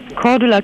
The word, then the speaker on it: Cordula: Cordula Kienes.